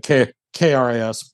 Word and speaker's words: Kris: I am Kris Burke, Burke. My first name is spelled weird. Blame my parents, not me.